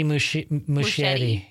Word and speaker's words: Muschietti: Is Muschietti? Muschietti? – Andy Muschietti.